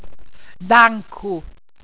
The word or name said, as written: dancku